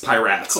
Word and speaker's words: Pyraatsen: L-O-C-K-E. Mm-hmm. Piratson. P-Y-R-A-A-T-S-E-N. – There's Piratson. – A double A. – Yes, Piratson.